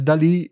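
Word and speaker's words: DALL-E: La più famosa è Dalí, che si dovrebbe pronunciare Dalí in onore del famoso artista pittore.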